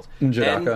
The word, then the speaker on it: N'Jadaka: It's like Njadaka. – Spell it. – it's N, then an apostrophe, which is why this is difficult. Njadaka. N-J-A-D-A-K-A. – Yeah, so Njadaka.